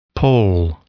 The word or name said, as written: pole